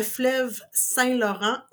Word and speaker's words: fleuve Saint-Laurent: I am going to try to get to Gaspésie and then cross the fleuve Saint-Laurent, or as you call it, the Saint-Laurence River. – Yep. – How do you like my pronunciation? – That's exactly how I say it.